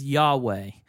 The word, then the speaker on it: Yahweh: And the Lord, when you see in your Bible, it's like the small caps, L-O-R-D, is a representation of God's name, which if you've heard it pronounced, is Yahweh.